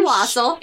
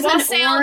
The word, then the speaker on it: wassail: There's a ramaki and wassail. – It's wassail, guys. It's wassail. – Okay. – I was taught how to pronounce that one because I had to sing that song.